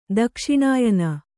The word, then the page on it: ದಕ್ಷಿಣಾಯನ: dakṣiṇāyana; kannada